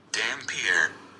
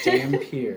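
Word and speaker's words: Dampyr: Damphyr. – That's how it's pronounced. – Thank you, Webster pronunciation. – Damphyr. – Damphyr. Okay, now we know.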